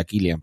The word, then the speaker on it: Cillian: Pero realmente el personaje de, de en realidad creo que se pronuncia Killian, pero bueno, Cillian Murphy,